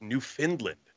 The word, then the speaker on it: Newfoundland: It's actually pronounced Newfoundland. Isn't that weird? – Newfoundland? – Yes. Hmm. Newfoundland. It's actually how it's like with the cadence and all that. It's Newfoundland. Yeah, because I do that all the time.